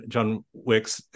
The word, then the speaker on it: Wicks: So and for people that don't know, you know, John that you're talking about, we're talking about uh, John John Wicks, and it's I have to remember how to, to say his name with the plural part, otherwise you get the movie thing.